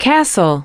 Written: castle

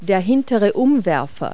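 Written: rear derailleur

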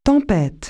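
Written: hurricane

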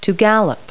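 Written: gallop

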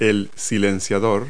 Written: muffler

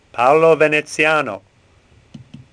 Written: Venezian

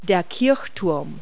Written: steeple

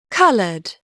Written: colored

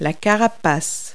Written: carapace

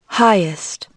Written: highest

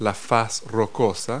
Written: rock face

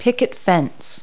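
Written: picket fence

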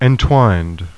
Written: entwined

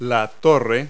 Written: tower

